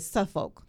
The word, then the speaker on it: Suffolk: So and you know how I know you're from Suffolk? Because you said Suffolk. The way you pronounce it. – Because that's what it is.